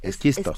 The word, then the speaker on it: esquisto: La fracturación hidráulica o fracking es una técnica de extracción con la que se puede obtener gas de exquisito, un hidrocarburo no convencional que se encuentra en capas de roca. Este, no lo dije bien. – esquisto. Esquisto, porque eh.